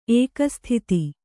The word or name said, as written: ಏಕಸ್ಥಿತಿ